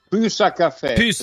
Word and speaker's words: Pussa Caffè: E poi Pussa? Com'è che si pronuncia bene? – Pussa Caffè.